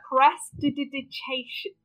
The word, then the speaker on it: Prestidigitation: Prestidigitation.